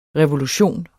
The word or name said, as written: revolution